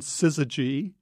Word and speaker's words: syzygy: S-Y-N-G-Y-Z-Y. What is that's right. – How do you say it? – It's called syzygy.